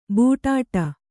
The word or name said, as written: ಬೂಟಾಟ